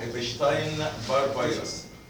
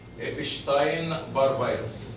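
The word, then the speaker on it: Epstein-Barr virus: الفيروس اسمه ايبشتاين بار فيروس. ايبشتاين بار فيروس. طيب طبعا الكلمة دي منها الماني، الاس دي في الالماني بتتنطق ايه؟ شين تي. الاس بتتنطق كانها شين، تبقى ايبشتاين بار فيروس.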